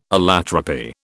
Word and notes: allotropy: British English

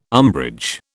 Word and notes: umbrage: British English

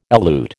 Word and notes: elute: American English

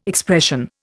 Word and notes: expression: Indian

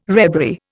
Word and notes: rebury: Indian

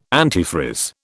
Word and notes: antifreeze: British English

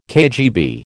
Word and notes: KGB: American English

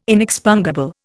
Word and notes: inexpungible: Indian

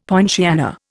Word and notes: poinciana: Indian

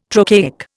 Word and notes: trochaic: Indian

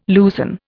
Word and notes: loosen: Indian

